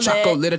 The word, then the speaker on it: chocolate: It's a chocolate attack. – Oh, have you ever heard it pronounced better? – Chocolate. – I mean, it is confusing because chocolate, you know, we don't pronounce the second O.